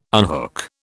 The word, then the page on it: unhook: British English